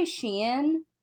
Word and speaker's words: Shein: There, I will say Shein, I think I'm saying that right.